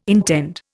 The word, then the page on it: intend: Indian